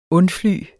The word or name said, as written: undfly